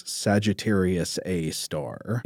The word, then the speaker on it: Sagittarius A*: This compact radio source together is known as Sagittarius A star. – And if you see this printed or typed, it is Sagittarius A asterisk. And that asterisk stands for star. – Right. It's pronounced star,